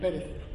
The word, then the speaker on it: Perez: Ulysses, U-L-I-S-E-S, and Perez, P-E-R-E-Z.